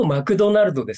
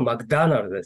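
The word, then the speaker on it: McDonald's: 日本だとマクドナルドですけども、英語発音だとマクダナルドです。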